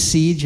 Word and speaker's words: Cej: My name is Siege, and I spell it the usual way. C-E-J.